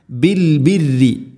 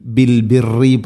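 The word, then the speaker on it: بالبر: ബിൽ ബിരി, പ്രിയമുള്ള ശ്രോതാക്കളെ പ്രത്യേകം ശ്രദ്ധിക്കുക, ബിൽ ബിരി, ബിൽ ബിറി പറയരുത്. റാഇന് ശദ്ദ് കസ്ർ, ബിൽ ബിറി അല്ല, ബിൽ ബിരി, ബിൽ ബിരി.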